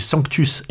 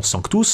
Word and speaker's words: Sanctus: N'oubliez pas que je dis Sanctus et non Sanctus, Sanctus Dominus Deus Sabaoth, car la prononciation se fait à la gallicane.